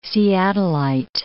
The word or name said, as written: Seattleite